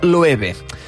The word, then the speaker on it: Loewe: Loewe es pronuncia Loewe.